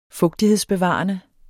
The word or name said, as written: fugtighedsbevarende